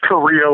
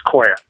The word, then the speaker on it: Correia: Well, in America, it's just Korea, like the country, and Portuguese is Correia.